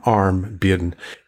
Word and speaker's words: Armbian: Armbian, as in ARM, Armbian.